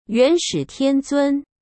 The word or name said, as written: yuanshitianzun